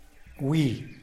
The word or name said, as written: gwi